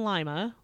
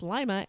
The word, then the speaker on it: Lima: He was captured by them and taken to jail in Lima, which, because I have lived in Ohio, I know is pronounced Lima and not Lima, like it's fucking spelled.